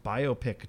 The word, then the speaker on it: biopic: Yeah, but it's like, oh, this is a biopic about, yeah, it's just biopic. Just sounds, I don't know, it sounds broken, but I know that's the correct way to say it, the way you said it.